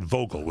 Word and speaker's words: Vogel: No, I said Vogel with a V. Did you? Frank Vogel. Yes.